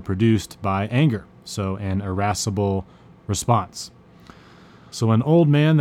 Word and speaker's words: irascible: This is a very fun word to say, irascible. It's spelled I-R-A-S-C-I-B-L-E. That's I-R-A-S-I-C-B-L-E, irascible.